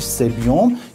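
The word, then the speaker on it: sebum: تلفظ درست حالا انگلیسیش سبیوم،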